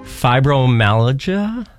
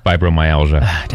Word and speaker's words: fibromyalgia: It's also, I always mess up how to say this, National Fibromyalgia? – Fibromyalgia. – Damn it. Awareness Day. You're close. Lady Gaga has that, actually.